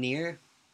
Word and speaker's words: Nier: It's from the game Nier. I think that's how you pronounce it. – I think so. N-I-E-R, Nier? – Yeah, with an uppercase R for some...